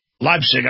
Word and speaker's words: Leipzig: The revolution started in the spring of 89 in the city of, how do I pronounce that? Leipzig. Okay, I knew I was getting it right.